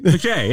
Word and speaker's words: McShay: I mean, we're McShay. I can't even say his name, right? – McShay, yeah. – McShay.